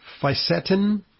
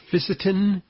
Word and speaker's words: fisetin: Strawberries are particularly rich in antioxidants, and they have a unique compound called, let me see if I get the pronunciation right, fisetin or fisetin, spelled F-I-S-E-T-I-N.